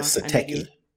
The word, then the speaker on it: Satechi: But I guess it's pronounced Satachi's. – Correct. I call it Sateki. – Sateki. Okay. Yeah. We'll go with Sateki.